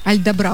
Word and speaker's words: Альдабра: Альдабра, вот так правильно говорить.